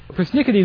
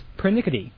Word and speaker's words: pernickety: Persnickety is the anglicized version. The correct pronunciation is pernickety. – I did not know that. That the word is actually pernickety.